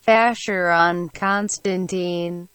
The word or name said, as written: Vacheron Constantin